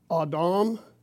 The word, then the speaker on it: Adam: The word is pronounced Adam. The Romanized spelling is A-D-A-M or Adam.